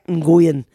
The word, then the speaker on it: Nguyen: Es que saben que se escribe, es Joseph, N-G-U-Y-E-N. Y-E-N. Entonces yo decía Nguyen. Y no, es Joseph Nguyen, ¿ok?